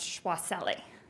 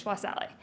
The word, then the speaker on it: Chakwaseli: The first defendant's last name is actually pronounced Chakwaseli, which is makes no phonetic sense, but it's Chakwaseli.